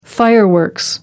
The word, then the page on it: fireworks: British English